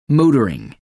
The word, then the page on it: motoring: American English